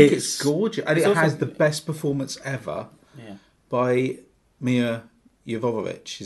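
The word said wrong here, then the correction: Milla Jovovich: Is that how you say it? Jovovich? – Mila Jovovich. – Oh, thank you.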